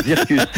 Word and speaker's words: Zirkuz: J'ai du mal à le prononcer, hein, Zirgus. Zircus. Zircus, voilà.